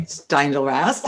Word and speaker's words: Steindl-Rast: Am I saying that right? – Steindl-Rast. That's okay.